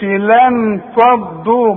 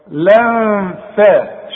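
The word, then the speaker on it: لنفضوا: ما تقولش لن فضوا ما تستعملش لسانك بقول لك لن فات شوف لن فات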